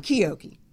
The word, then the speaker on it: Chiocchi: Chiocchi.